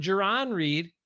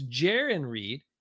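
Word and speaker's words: Jarran Reed: Not Jerron Reed, like a lot of people said early on. It's Jerron Reed. And Jerron rhymes with Darren, is doing pretty well.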